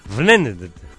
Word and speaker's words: Wnendt: Vnendt.